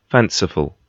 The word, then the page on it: fanciful: British English